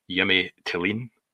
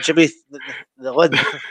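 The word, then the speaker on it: Jimmy Thelin: Jimmy Tillin. – Oh, so it's not Jimmy the Lynn. – No, so I'm going to go with Derek Ray's advice. He's he suggested that it's Jimmy Tillin.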